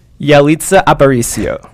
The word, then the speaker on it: Yalitza Aparicio: Yalitza Aparicio.